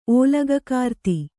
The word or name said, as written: ಓಲಗಕಾರ್ತಿ